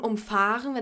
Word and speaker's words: umfahren: Und umfahren, wenn es halt auf fahren ist, die Betonung.